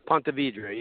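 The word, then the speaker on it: Ponte Vedra: So is it Pontevedra? Did I say that right? – Pontevedra, yeah.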